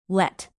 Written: let

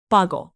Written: boggle

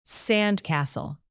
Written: sandcastle

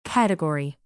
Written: category

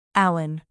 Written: alan

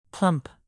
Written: plump

